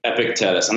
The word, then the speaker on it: Epictetus: Epictetus.